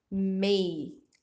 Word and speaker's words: may: Tomar bastante cuidado com a pronúncia. Esse aqui a gente fala may.